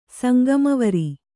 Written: ಸಂಗಮವರಿ

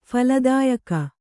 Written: ಫಲದಾಯಕ

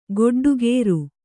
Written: ಗೊಡ್ಡಕಾರ

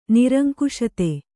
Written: ನಿರಂಕುಶತೆ

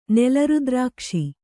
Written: ನೆಲರುದ್ರಾಕ್ಷಿ

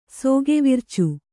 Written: ಸೋಗೆವಿರ್ಚು

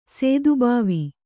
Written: ಸದ್ಭಾವ

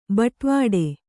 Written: ಬಟ್ವಾಡೆ

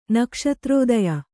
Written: ನಕ್ಷತ್ರೋದಯ

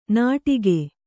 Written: ನಟಿಕೆ

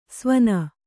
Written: ಸ್ವನ

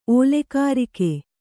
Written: ಓಲೆಕಾರಿಕೆ

